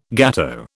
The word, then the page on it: gateau: British English